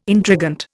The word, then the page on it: intrigant: Indian